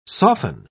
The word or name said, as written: soften